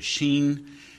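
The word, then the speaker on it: xīn: But you'll notice that underneath the characters are written some what's called romanization, so that X-I-N, X is pronounced like an S-H, and you notice the I has kind of a flat line over it. That means it's said with a level tone, so it's Sheen, and then the next word is Nien, and you notice it's got a mark that starts and goes up.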